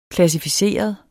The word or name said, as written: klassificeret